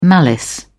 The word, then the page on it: malice: British English